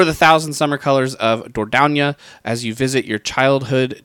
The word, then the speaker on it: Dordogne: I also Googled how to say the name, and unless Google is telling me incorrectly, it is Dordogne. Dordogne. – Dordogne.